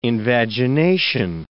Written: invagination

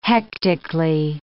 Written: hectically

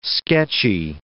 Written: sketchy

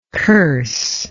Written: curser

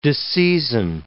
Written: disseisin

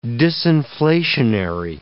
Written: disinflationary

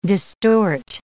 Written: distort

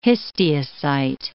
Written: histiocyte

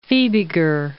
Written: Fibiger

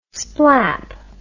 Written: splap

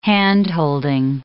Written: hand-holding

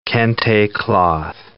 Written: kente cloth

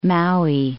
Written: Maui

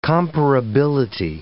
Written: comparability

